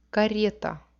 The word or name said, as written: kareta